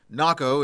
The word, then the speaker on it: Naco: Naco is about 80 miles southeast of Tucson. It also rhymes with taco.